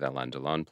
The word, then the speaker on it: Alain Delon: There's also Purple Noon, with Alain Delon plays Ripley.